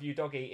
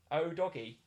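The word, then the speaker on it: Udogie: People are saying that my pronunciation of Udogi is absolutely right. Pronunciation is fine. Udogi. There you go.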